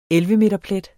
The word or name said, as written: 11-meterplet